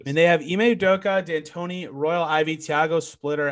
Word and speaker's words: Udoka: Is it Ime Udoka? – Ime Doka. – Udoka.